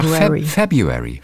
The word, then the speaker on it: February: Man sagt einfach February. – February. – Ja, obwohl dann RU drin ist, ist ja, das Wort ist einfach fies.